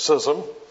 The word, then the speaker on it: schism: that's the way you pronounce it, schism, in the Trinity.